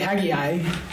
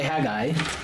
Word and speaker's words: Haggai: You could say Haggai, or you could say Haggai.